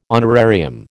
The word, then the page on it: honorarium: American English